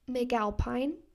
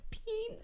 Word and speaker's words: McAlpine: First is Ceilings by Lizzie McAlpine. How do you say it? – Yeah, it could be Alphine. – McAlpine? – Yeah, I don't know. – Peen. – Okay. – Okay, sorry.